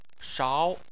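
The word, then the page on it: shao: tone 2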